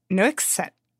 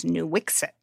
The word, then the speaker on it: Nuiqsut: And it's called Nuiqsut. This is a hard one. It is Nuiqsut. It's I actually, you know, have been trying very hard to pronounce it correctly. The official English pronunciation that the state of Alaska has is Nuiqsut.